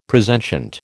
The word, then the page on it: presentient: American English